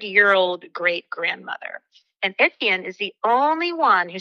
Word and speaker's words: Etienne: Etienne, E T I E N N E.